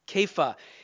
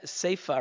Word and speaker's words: Kepha: The nickname that he was given by Jesus in Aramaic, which was the common language the people were speaking, was Kepha. In English, you'll see it spelled C-E-P-H-A, so some people want to say Sepha or Sepha, but it's pronounced Kepha, and it means rock.